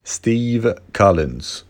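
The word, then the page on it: Steve Collins: English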